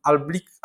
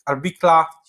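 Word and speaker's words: Albicla: W ogóle prezes Kaczyński no, rozmawiał też portalem Albik, Albikla, wymawia,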